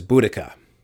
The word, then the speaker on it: Boudica: Uh, so I will instead refer to her as Boudica because that is probably closer to her actual pronunciation of her name. Uh, the Welsh would say it a little bit differently. Uh, and I don't think I could get the pronunciation quite right, but it's something along the lines of Budbrug.